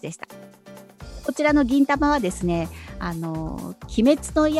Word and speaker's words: 銀魂: あの銀色の銀に魂と書いて銀魂と読みます。